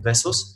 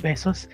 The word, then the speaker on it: Bezos: Eh, normalmente cuando escucho Jeff Bezos eh, pronunciado en inglés no suena a besos, pero en español sí suena más a besos.